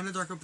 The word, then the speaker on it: Anadarko: Anadarko Petroleum.